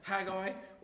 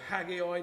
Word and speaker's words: Haggai: And here, let's, if, you, if you've got your Bible, turn to Haggai or Haggai, depending which way you like to pronounce it, chapter 1.